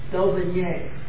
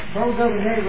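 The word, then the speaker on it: d'Aubigné: Merle Daubigny, especially with the southern pronunciation of a French name. J.H. Merle Daubigny.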